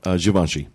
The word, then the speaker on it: Givenchy: And then also Givenchy, is that how you say it? – Givenchy. – Givenchy.